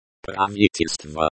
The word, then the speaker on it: Правительство: Правительство. – Правительство, government. – The stress is on the second syllable. The O at the end of the word is pronounced like the O in the word go.